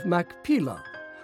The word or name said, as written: Machpelah